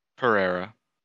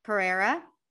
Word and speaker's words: Pereira: Everson Pereira. – Pereira. – Pereira.